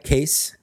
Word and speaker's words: Case: Mike Case is my last name, because it's there, it's on YouTube, and a lot of people mispronounce it, so I like to just say that. – Siri pronounces your name as Mike Cass, yeah.